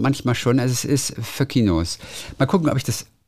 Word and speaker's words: Foenkinos: Also es wird wohl ausgesprochen Föckinos. Obwohl das N drin ist, Foenkinos, heißt es wohl Föckinos.